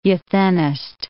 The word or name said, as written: euthenist